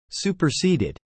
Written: superseded

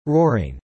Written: roaring

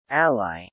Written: ally

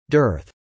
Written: dearth

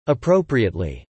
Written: appropriately

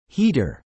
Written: Heater

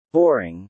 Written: boring

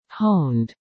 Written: honed

